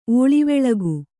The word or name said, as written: ಓಳಿವೆಳಗು